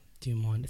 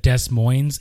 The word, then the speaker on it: Des Moines: Des Moines, Iowa, April 20. Oh, no, I'm sorry. April 17th, Fort Lauderdale, Florida. April 24th, Des Moines, Iowa. – Des Moines.